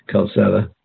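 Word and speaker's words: Calçada: Yeah, there's a, for those of you, there's a Cedilla under the C, and it's a soft C, Calzada.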